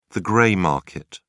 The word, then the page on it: grey market: British English